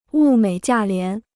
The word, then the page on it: 物美价廉: wù měi jià lián